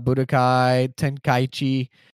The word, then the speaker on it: Budokai Tenkaichi: They said they're bringing back the gameplay, Budokai Tenkaichi.